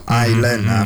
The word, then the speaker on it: island: So it's written island, but you do not read it as Island. No, the S is silent. So island, for example, like that.